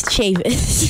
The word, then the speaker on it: Chavis: Dude, I watched you say your own name like six times this morning, and I even wrote it out phonetically. Chav-IS, and I still messed it up.